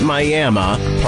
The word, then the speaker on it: Miami: You'd think it's Miami, but they pronounce it Miami, part of the Miami Indians, a great Native American tribe.